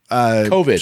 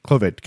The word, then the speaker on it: COVID: And today on the show, well, we try to do our best to combat the coronavirus, C-Void 19, or is that how you say it? Is it C-Void? COVID. – Sure. COVID. COVID-19.